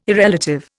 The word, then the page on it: irrelative: Indian